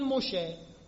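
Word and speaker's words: مشه (משה): در زبان عبری خیلی وقتا، نه همیشه، سین رو شین تلفظ میکنن، سلام رو میگن شالوم، نسا رو میگن نشاییم، موسا رو میگن مشه، مسیح رو میگن ماشیح.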